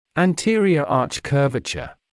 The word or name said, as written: anterior arch curvature